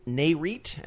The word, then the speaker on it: NAREIT: He's an economist at NAREIT.